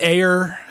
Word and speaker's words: Ayer: It's directed by David Ayer.